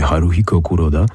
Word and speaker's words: Haruhiko Kuroda: Japończycy wymawiają go tak bardziej Haruhiko Kuroda.